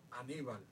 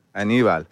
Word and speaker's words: Aníbal: How do you say the name? Anibal? Remember Anibal from the Taínos? – Oh, Aníbal. – Aníbal. Mm-hmm. So those were the characters, because I obviously don't pronounce it correctly.